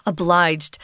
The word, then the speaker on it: obliged: We use a hard G on obligation, or I feel obligated. That's a hard G. But obliged suddenly turns into this soft G sound, this J obliged. Um,